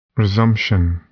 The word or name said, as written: resumption